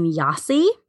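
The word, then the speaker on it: Gyasi: It's by I believe you say her last name, Yasi. I think the G is silent. It's G-Y-A-S-I.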